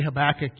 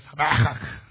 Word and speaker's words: Habakkuk: When you say Habakkuk, you have to say it like this, Habakkuk. Like a Jewish person would say it, Habakkuk.